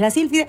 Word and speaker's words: Sílfide: Bueno, se castellaniza y me parece bien que es La Sílfide.